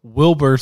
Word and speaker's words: Wilbur: So here's episode 3 with Wilbert Soot. I just said his name wrong. Wilbur Soot.